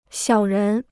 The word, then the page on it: 小人: xiǎo rén